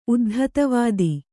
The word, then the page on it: ಉದ್ಧತವಾದಿ: Kannada